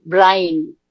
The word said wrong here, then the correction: Byrne: No, es Byrne. Es Burn. – B-Y-R-N-E. RNE. Hay que pronunciar la Y como I, Bern.